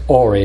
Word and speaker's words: Ori: Actually, it's Ori, not Uri, but very, very similar in the Hebrew.